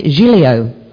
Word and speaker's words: Giglio: Well, I looked it up and I had them pronounce it over the thing, and they call it Giglio.